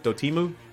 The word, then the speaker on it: Dotemu: experiment This is coming straight from uh, Tribute Games and um, Dotemu, right? That's how you say it. – Yeah, uh, yeah, yeah, yeah, yeah. Uh, Dotemu, Dotemu, yeah.